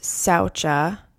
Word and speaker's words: saucha: So we're starting with saucha, literally meaning purity, pronounced saucha.